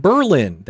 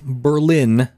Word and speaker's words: Berlin: All the towns by that name in New England, we pronounce them Berlin. That group, though, Berlin. Know the difference. Don't want to sound like a flatlander.